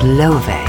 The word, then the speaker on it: Laufey: And as far as I can work it out, her name is Love. It's spelled L-A-U-F-E-Y, but I watched an interview on her, Love, I believe I've got it right,